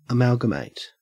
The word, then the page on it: amalgamate: Australian English